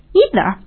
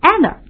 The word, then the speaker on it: either: 我们说它一般都是用在肯定句或疑问句当中，也表示也，但是它不可以用于否定句。那么否定句大家还记着，我们应该用 either 或者是 either 两种读音都可以 E-I-T-H-E-R。